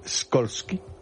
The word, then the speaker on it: Skolsky: No, señor, no es SH, es SKOLSKY. – Impronunciable. – A la señora. – ¿Cómo se pronuncia? – Porque es polaco. ¿Cómo se pronunciaría en polaco Skolsky? – Skolsky. Como tú lo has dicho.